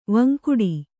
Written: ವಿಂಗಡಿ

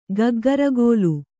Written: ಗಗ್ಗರಿಗೋಲು

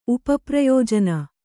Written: ಉಪಪ್ರಯೋಜನ

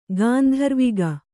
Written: ಗಂಧರ್ವಕ

